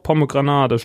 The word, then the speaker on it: pomegranate: Doch, Pomegranate steht hier.